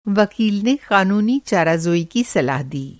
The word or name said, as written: क़ानूनी-चाराजोई